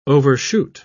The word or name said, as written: overshoot